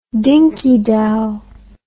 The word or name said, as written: dinky dow